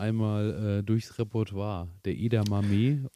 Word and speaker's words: Edamame: aber Edamame oder Edamame äh, ist dann tatsächlich auch in verschiedensten äh, Ausspracheweisen äh, äh, irgendwo zu finden. Äh, also aber, ich hoffe, ihr seht es mir nach. Die, dieses Edamame, einigen wir uns einfach mal da drauf.